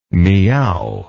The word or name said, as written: Miao